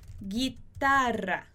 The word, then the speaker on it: guitarra: E aí, quando a gente tem esse g e esse gui, o U não vai ter som. A gente não vai pronunciar nem esse U do g, nem esse U do gui. Por exemplo, eu posso falar a palavra guerra, guerra. E a palavra guitarra, guerra, guitarra. A gente não pronuncia esse U.